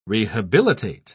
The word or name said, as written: rehabilitate